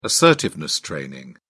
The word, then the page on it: assertiveness training: British English